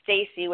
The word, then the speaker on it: Stacey: So my name is spelled Stacey with an E, S-T-A-C-E-Y. Golden-Lisnock. So it's Golden, G-O-L-D-E-N, hyphen Lisnock is L-I-S, like Sam, N like Nancy, O-C-K.